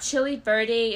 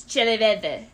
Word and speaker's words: chile verde: It's not chili verde, it's not chili verde, it's chile verde.